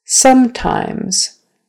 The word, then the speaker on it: sometimes: Remember, it's a on that first syllable. Sometimes. Accent is on the first syllable. The stress is on the first syllable. Sometimes. And don't forget the Z sound at the end of the word.